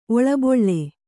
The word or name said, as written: ಒಳಬೀಳ್